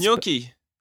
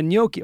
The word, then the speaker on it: gnocchi: This will be the last it's gnocchi. – Oh yeah, gnocchi.